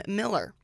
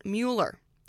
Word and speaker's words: Mueller: The airport was named after a man who pronounced his name Miller. But when people refer to the redeveloped neighborhood, many pronounce it Mueller.